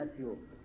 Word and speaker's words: أسيوط: الإمام السيوطي لعلكم يعني لمحتم من خلال تسميته أنه من نصر ومن أسيوط، وتنطق سيوط أيضا بغير همز في أولها كما حققه يقود في معجم البلدان، يعني كلاهما سواء إن قلت أسيوط أو قلت سيوط.